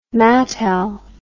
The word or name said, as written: Mattel